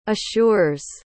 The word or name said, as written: assures